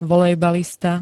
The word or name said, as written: volejbalista